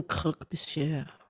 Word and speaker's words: croque monsieur: J'aime croque monsieur.